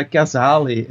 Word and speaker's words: Kazale: uh, It's uh, Kazale.